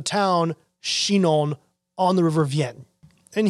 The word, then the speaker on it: Vienne: a tributary breaks off called the River Vienne. I believe it's pronounced that way, V-I-E-N-N-E, very similar to the town in the southern Rhone we talked about, but no connection really.